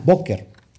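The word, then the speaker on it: Boker (בוקר): Ahora vamos con la mañana, Boker. La mañana, Boker. Y está, está, está, se escribe así, Boker, con la letra Bet, Kuf y Reish.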